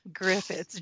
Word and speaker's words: Griffiths: Is Griffiths, G-R-I-F-F as in Frank, I-T-T as in Tom, S. There's no H, there's no N, and I am not Mrs. Griffitius.